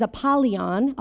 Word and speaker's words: Apollyon: If you look up that word where the Satan comes to steal, that word for Satan is Apollyon. I'll spell it for you, A-P-O-L-L-Y-O-N, Apollyon.